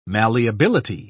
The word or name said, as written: malleability